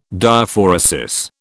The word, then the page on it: Diaphoresis: British English